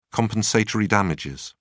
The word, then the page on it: compensatory damages: British English